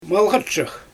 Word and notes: malxatshyk: Xyzyl

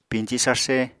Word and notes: piñci sarsé: Apatani

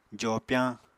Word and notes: jopyañ: apatani

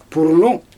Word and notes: purrnu: Xyzyl

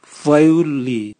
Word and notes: vwaiuli: Raga

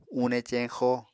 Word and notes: une ceñxo: Apatani

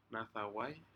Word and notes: nadawai: Aneityum

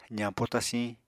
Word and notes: zañpo tasiñ: apatani

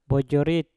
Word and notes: pojorit: Mpur

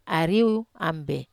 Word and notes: ariou ambe: Wamesa

